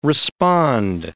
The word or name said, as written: respond